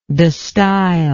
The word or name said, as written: destijl